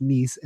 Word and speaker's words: Nice: And I'm amazed that I actually pronounced that correctly, that it's Nice and not Nice, because I really just want to say Nice 100 times every time I read it.